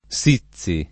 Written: Sizi

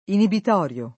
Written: inibitorio

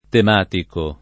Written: tematico

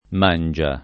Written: Mangia

